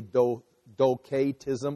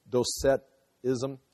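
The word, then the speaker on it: Docetism: So this C could be pronounced with a K, Doketism, or Doceticism.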